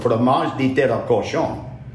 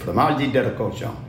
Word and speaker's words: fromage de tête à cochon: In Cajun French, we call it fromage de tête à cochon. That's how you say it. Fromage de tête à cochon.